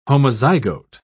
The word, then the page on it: homozygote: British English